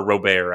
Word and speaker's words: Robert: Luis Robert, or Robert, actually, is how it's pronounced. I was going to say, isn't it Robert? – Yeah, it's Robert, excuse me.